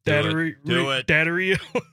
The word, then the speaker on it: Daddario: Daddario.